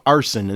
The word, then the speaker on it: Arsun: A young man by the name of Arson, and it's spelled A-R-S-U-N, as opposed to the act of setting fire to things. Arson is New York-based singer-songwriter Arson Sorrenti.